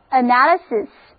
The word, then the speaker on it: analysis: 那么如果我们把 analyze 后面的 e 去掉，加上 ic 的名词后缀，面前出现的这个单词是 analyze 的名词，重音后移了，读作 analysis， analysis， analysis。